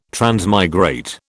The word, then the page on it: transmigrate: British English